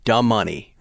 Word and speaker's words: Da Money: D-A, duh, M-U-H hyphen N-E-Y. Da Money.